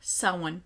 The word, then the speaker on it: Samhain: Well, and he talks about Samhain. – Someone. – Yeah, whatever. Until Jacksepticeye tells me that that's how you say it, I'm going to call it Samhain.